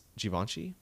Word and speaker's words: Givenchy: Givenchy. – Is that how you say it? Nice. Givenchy? – Yeah.